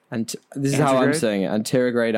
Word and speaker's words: anterograde: How do you say it? – Anterograde amnesia. – Anterograde amnesia. There you go.